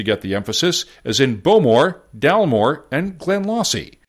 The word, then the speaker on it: Bowmore: I pronounced it Bowmore instead of the proper Bowmore.